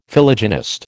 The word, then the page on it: Philogynist: American English